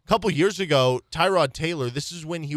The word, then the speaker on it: Tyrod: And he came out and said, yeah, my name is actually pronounced Tyrod Taylor. – Mm. – And most people just have kind of blown by that and just been like, yeah, we've been calling you Tyrod for so long.